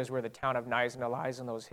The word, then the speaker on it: Knysna: The town that we're going to is, as you see it there in the bottom with the K, it's a silent K, it's called Knysna, South Africa.